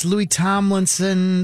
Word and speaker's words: Louis Tomlinson: Now, you sent me some stories about this Louis Tomlinson. That's how you say his name, right? It's not Louis.